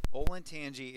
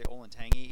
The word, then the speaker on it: Olentangy: And then if you look at it from a larger perspective, it's a really weird word. Most people don't pronounce it right. They say Olentangy. Even Siri calls it Olentangy, by the way.